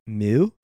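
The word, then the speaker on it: Mø: And then, so Mo, which I believe it's M with the O slashed out. And I was looking online. I believe you pronounce it like Mew. – Oh. – And yeah, sort of like Mew Mews.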